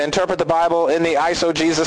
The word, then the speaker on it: eisegesis: And how to pronounce that is eisegesis. I know it doesn't look like it says Jesus, but the word is pronounced eisegesis.